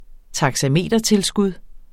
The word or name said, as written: taxametertilskud